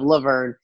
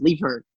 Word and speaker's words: Levern: Because it's crazy because my dad will be like, your name's actually pronounced Levern, you know, and then like my mom will call me Laverne, and he'll be okay with it, and he'll call, it's weird, man.